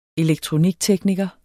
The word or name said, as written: elektroniktekniker